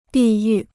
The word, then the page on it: 地域: dì yù